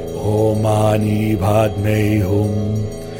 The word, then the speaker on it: Om Mani Padme Hum: Often called the Great Mani, it is pronounced Om Mani Padme Hum.